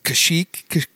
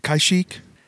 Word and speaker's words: Kashyyyk: That would be, I can't ever say it right, but it's spelled like K-Y, like two Y's. It's Kashyyyk? Kashyyyk? – That is correct.